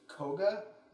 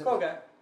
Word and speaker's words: Kouga: Yeah, like, the main character's name is Koga, is how they pronounce it. – Koga. – K-O-U-G-A. – Koga. – And oh, yeah, like the Pokemon gym leader. – Yeah. – And then the main female's name is, it's K-A-R-O-U.